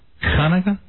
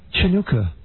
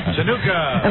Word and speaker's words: Chanukah: Hanukkah? – Chanukah. No. – Chanukah.